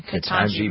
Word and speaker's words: Ketanji: Ketanji Brown Jackson, yeah.